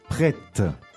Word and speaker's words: prête: Prête. – Prête means ready. – Yeah. – Now, this is the feminine form, prête. – That's why we pronounce the T.